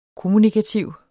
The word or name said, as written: kommunikativt